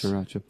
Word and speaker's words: Sriracha: And it's spelled Sriracha. – Yeah, that first R is silent. Yeah. – Yes. – Sriracha.